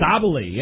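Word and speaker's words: Sabally: Am I saying it right? Sabali? – Sabali, yeah.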